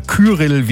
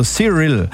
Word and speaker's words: Cyril: Cyril oder Kyrill wie der Sturm, aber es spricht sich wie Sir, also Cyril.